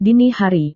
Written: dini hari

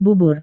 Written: bubur

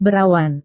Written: berawan

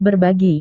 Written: berbagi

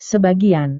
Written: sebagian

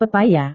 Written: pepaya

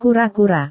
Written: kura-kura

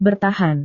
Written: bertahan

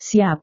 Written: siap

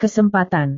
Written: kesempatan

